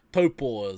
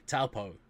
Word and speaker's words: Taupo: If you want central, Taupo is where it's at. Yeah, I like that pronunciation. Taupo, sorry for all you whiteies.